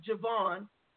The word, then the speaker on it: JaVonne: So if, like, my name is JaVonne, and it's spelled J-A, it's capital J-A, capital V-O-N-N-E.